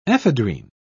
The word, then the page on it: ephedrine: British English